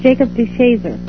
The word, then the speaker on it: DeShazer: And Carol's father, his name was Jacob DeShazer, is it? That's pronounced? – Yes, it's Jacob DeShazer.